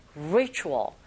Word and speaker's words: ritual: Ritual 발음하실 때 R 사운드 처음 소리 내실 때 잘 안 되시면 힌트는요. 우 하면서 re 하시면 돼요. Ritual. 그런데 이때 혀는 어디에도 닿지 않게 발음하셔야 됩니다.